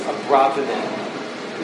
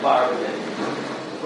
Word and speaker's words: Abravanel: The correct way to pronounce his name is Abravanel. You could call him Abravanel.